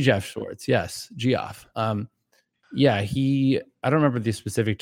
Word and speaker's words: Geoff: Geoff. Yeah, Geoff Schwartz. Yeah, he... – You realize that's just pronounced Jeff also.